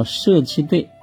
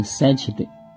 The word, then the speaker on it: 塞其兑: 那个叫舍其兑。不能念成三七对，啊，它的意思不同。